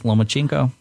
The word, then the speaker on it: Lomachenko: I can pronounce Lomachenko.